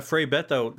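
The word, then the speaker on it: Frei Betto: Yeah, you have an anecdote about, I'm going to mispronounce this, but Frei Beto, is that correct? – Yeah, Frei Beto was a Brazilian liberation theologian.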